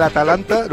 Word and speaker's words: Atalanta: Sí, bueno, pero y antes del Atlanta dije, si el Madrid pasa la eliminación, Atlanta, la eliminatoria del Atalanta, lo he dicho bien hoy. – Ahora sí.